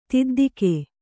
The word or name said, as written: ದೊದ್ದೆಗ